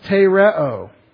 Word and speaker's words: tereo: He who has the commandments, the entole, of mine, and tereo, T-E-R-E-O, te, long E, R-E-O.